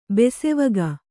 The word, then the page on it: ಬಾಸೆವಕ್ಕಿ: kannada